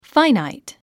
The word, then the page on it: finite: fǽinait; ファイナァイト